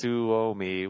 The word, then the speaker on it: Suomi: It's Suomi, which is Finnish for Finland. – Oh. So, there you go. – I don't know why I pulled that up, but Suomi.